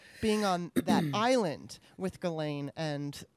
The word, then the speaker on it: Ghislaine: Unfortunately, so did his girlfriend, who I'm not sure if I'm going to say her name right, which is, I think, is Ghislaine Maxwell. – That's what I heard, Ghislaine.